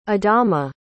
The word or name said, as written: adama